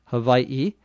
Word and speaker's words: Hawaii: Hawaii is spelled H-A-W-A-I-I. In the island itself, the people who live there call it Hawaii, where the W becomes like a V.